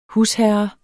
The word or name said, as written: husherre